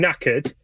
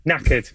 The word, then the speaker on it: knackered: If I'm knackered, am I stupid, broke, tired, or crazy? – Oh, this one's C.